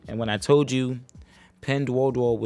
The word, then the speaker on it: Pinduoduo: It says Penduoduo. It's spelled P-I-N-D-U-O-D-U-O is the company.